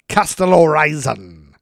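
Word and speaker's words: Kastellorizo: Falling 5 miles, the jet struck the water near the Greek island of Kastellorizon. That's exactly how you say it.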